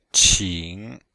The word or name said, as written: qing3